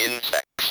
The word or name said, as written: insects